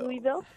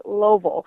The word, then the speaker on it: Louisville: I always call it Louisville, but I got a crash course on how to say it properly. Just put your fist in your mouth and say Louisville.